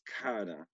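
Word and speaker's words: Kata: when we were talking about like how you like to, what you go by, you phonetically spelled it to me, Kada. – Kada. – It's Kada.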